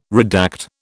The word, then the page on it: redact: British English